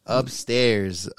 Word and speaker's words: UPSTRZ: It's probably spelled like fucking U-P-S-T-R-Z or some shit. – Yeah. That's how it's spelled. U-P-S-T-R-Z. Upstairs.